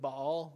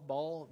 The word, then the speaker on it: Baal: You familiar with Baal? Baal? Baal? Some people pronounce it Baal. B-A-A-L.